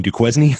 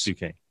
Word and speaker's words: Duquesne: Oh, you mean Duquesne? – Duquesne.